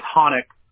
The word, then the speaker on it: Chanowk: It's a hard name, spelled, and this doesn't help at all, but the spelling, first name is C-H-A-N-O-W-K. – Okay, Tanik. It's Tanik. – It's Honick. It's like Hanukkah without the, so you spell it C-H-A-N-O-W.